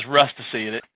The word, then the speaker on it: Rustici: The proper way, the way we say it, is Rustici.